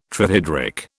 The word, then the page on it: trihydric: British English